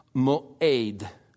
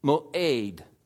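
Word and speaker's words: mo'ed (מועד): And so we have a word in the Old Testament that is pronounced mo'ed, mo'ed.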